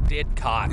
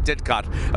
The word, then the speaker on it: Didcot: We have had an exhausting week in the little English town of Diet Coke. No, what's it called? What was it called? – Didcot. – Excuse me, Didcot. Americans never know how to pronounce English towns.